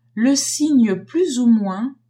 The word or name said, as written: signe plus ou moins